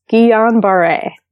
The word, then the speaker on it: Guillain-Barré: I learned how to say this word from writing this article. Actually, it's Guillain-Barré.